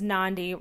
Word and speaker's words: Nandi: My name is Nandi, rhymes with Gandhi.